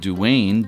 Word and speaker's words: duan: The similar sounding Irish word duane, D-U-A-N, means hook,